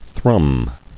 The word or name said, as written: Thrumming